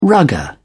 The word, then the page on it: rugger: British English